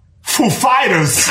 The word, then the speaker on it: Foo Fighters: And he comes up, and he's like, he asked us if the accent was on Foo or Fighters? And we know who he is. Like, of course, we know how he speaks. And we said, the accent is on Fighters, actually. So he goes up, he's like, ladies and gentlemen, Foo Fighters.